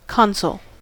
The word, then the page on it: console: American English